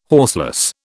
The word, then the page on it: horseless: British English